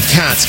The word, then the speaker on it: Katz: By the way, my last name is spelled Katz, K-A-T-Z.